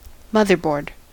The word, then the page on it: motherboard: American English